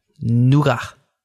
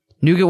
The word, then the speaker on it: Nougat: Nougat. – Nougat? – Nougat. – As not nougat? – Pronounced in its native tongue. – Now, what is nougat's native tongue? – French.